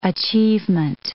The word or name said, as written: achievement